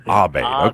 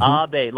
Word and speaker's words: Abade: And pronounce it again. – Abade or Abade? – Abade. Okay, there we go. – Yes, Abade. Yes. Mm-hmm. Abade, like a serenade, but it's an Abade.